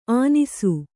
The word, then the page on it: ಆನಿಸು: ānisu